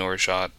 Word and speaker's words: orgeat: And so making orgeat, that is the correct pronunciation,